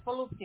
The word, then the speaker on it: Volusia: That's in Volusia County, or I think that's how you say it, Volusia County? Is that correct? – Yes, Volusia. Yes.